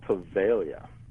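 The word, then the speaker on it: Poveglia: I can't say it. Pavaliga. What? – It's called Pavaglia. – Ah, Pavaglia. Okay. – A silent G.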